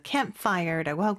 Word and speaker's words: campfire: C-A-M-P-F-I-R-E. – 네, 한 단어죠. 그쵸? 그래서 campfire라고 하고요. 우리는 캠프파이어 다섯 개 음절이지만 영어로는 두 개 음절로 campfire 이렇게 발음을 합니다.